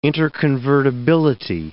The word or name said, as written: interconvertibility